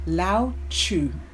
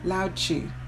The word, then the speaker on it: Lao Tzu: Like right now in front of me, I have got these lovely words, and I believe it's by, let me pronounce the name, Lao Tzu. Lao Tzu. L-A-O is the first word, the first name, and the surname is T-Z-E-U.